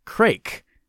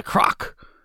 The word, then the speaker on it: craic: It's not C-R-A-C-K, like you might think. Actually, it's spelt C-R-A-I-C. At first, I thought it would be pronounced crake, but a quick search on YouTube showed me I was wrong about that.